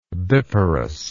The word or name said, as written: biparous